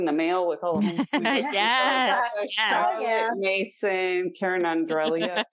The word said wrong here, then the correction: Andreola: Am I going to say it right, Holly? – Andreola.